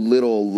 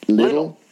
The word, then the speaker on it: Lidl: I don't know what you how you pronounce it in, in English. That German uh, supermarket uh, chain, L I D L, Little.